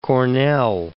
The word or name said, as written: Cornell